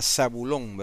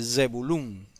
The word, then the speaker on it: Zebulún: Es lo que en las Biblias tradicionales se llama Zabulón, ¿verdad? Eso viene del griego, esa pronunciación. Zabulón viene del griego. En hebreo se dice Zebulún, con Z al principio.